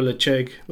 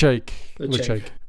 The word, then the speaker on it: Ledaig: I would go Lechug with this. – Lechug. Okay, yeah. Nice. Lechug, so there we go, Lechug Distillery.